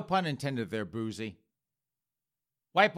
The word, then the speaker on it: Boosie: You spell that, just in case you're wondering, B-O-O-S-I-E, that's Boozy, and Badass, that's B-A-D-A-Z-Z.